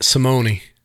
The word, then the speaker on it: Simone: Matt Simone. – I've heard Simone and I've heard Simone or Simone A. – It technically has the long E. Yeah, it's Italian.